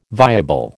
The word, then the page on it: viable: American English